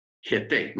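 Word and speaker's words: Jete (חטא): Ahora, la, en, hebreo la palabra pecado se pronuncia jate o jete. Jete, más bien jete.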